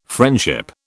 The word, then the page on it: friendship: British English